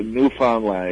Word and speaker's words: Newfoundland: Sorry, I know I said it wrong. – Newfoundland, yeah, Newfoundland.